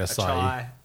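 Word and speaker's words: açaí: I've heard people say acai. – Acai. – Acai. – Acai. – I think, yeah, chai, acai. Yeah, A-C-A-I.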